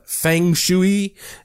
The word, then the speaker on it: feng shui: it's spelled F-E-N-G-S-H-U-I. Some people call it feng shui. I've heard all kinds of pronunciations, but I have done my best to research what the old ancient Chinese masters have said, and it is called feng shui.